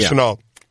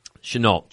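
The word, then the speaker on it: Shenault: He says Colorado wide receiver, is it LaVisca Chenault? How do you say this name? Chenault? – I think it's Chenault. – Chenault? – Yeah, it's AU, mm-hmm. It's AULT, correct? – Yeah. Chenault.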